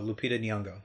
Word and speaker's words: Lupita Nyong'o: Oh, Lupita Nyong'o.